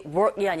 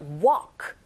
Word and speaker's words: walk: Actually, work이 아니라 walk. 이렇게 발음하셔야죠.